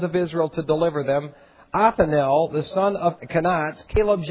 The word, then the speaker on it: Othniel: Othniel is how you pronounce it.